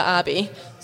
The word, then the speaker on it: Abbie: So my Instagram is Bria Abbey. So my name is spelled B-R-E-A-G-H and then Abbey, A-B-B-I-E.